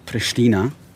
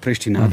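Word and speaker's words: Prishtina: Also da haben wir es schon gehört, dass also erstens mal die Aussprache, ne? Pristina, nicht Pristina, da haben wir auch was dazugelernt hier. Also wenn man es Albanisch, was ja hier die Hauptsprache ist, ausspricht, dann ist es also Pristina, dass sie Dua Lipa also ankündigt, diese Kunstbiennale, dass das also durchzogen wird, die Stadt von Kunst und Installation.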